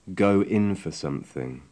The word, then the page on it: go in for sth: English